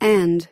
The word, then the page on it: and: American English